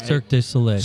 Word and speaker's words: Cirque du Soleil: But right now, you got Cirque du Soleil going on, if I'm even saying that right. – Cirque du Soleil.